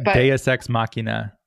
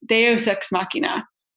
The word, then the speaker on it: Deus Ex Machina: But instead, what happened was halfway through the final season, they just had the, there was sort of like a Duke's Ex Machina Duex Machina. I don't know how to pronounce it. I think Deus Ex Machina. – Yes, Deus Ex Machina,